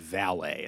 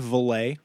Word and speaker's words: Valais: In England, they pronounce that valet, and that's stupid. Have you heard of Valais? – No.